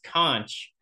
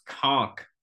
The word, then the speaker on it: conch: way, everyone who's listening, it's not pronounced conch. It's pronounced conk.